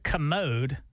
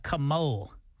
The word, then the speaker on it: commode: He can't say the word commode, mm-hmm. which I don't know why he says commode anyway. He says commole.